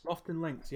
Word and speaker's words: Lofoten Links: But it's, I'm not quite how to pronounce it, but Lofoten Links or Lofoten Links. – Lofoten Links, yeah.